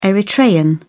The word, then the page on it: eritrean: British English